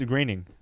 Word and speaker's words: Groening: It's Groening. Because it's Green? O and E, and for some reason the E, the O is silent.